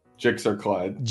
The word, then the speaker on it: GSXRClyde: GSXRClyde.